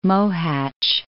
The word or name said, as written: Mohacs